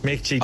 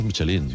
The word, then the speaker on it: Michelin: Oye, Michelin, ¿cómo se pronuncia definitivamente? – Michelin. A ver, aquí en la Península Ibérica decimos Michelin. – Sí, claro. Claro, pero la realidad, tú eres un chef internacional. – Ya, pero ¿tú cómo lo pronuncias? – Me cuesta ser tan tontorraco, ¿no? Dice Michelin. – ¿Michelin? – Yo digo Michelin. – Vale. – Y cuando vas a una gala, pues escuchas Michelin a veces.